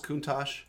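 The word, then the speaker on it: Countach: Is it pronounced Kuntosh? – Yeah, like the car.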